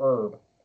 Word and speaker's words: herb: Is it herb or herb? – Herb? – Herb? Herb. How do you pronounce that? – It's herb. – Herb? So we got one herb and one herb. – Don't pronounce the H. Herb.